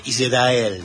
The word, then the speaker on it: Israel: ¿Por qué ellos tienen una forma de pronunciar Israel que es como arrastrando la R? Israel.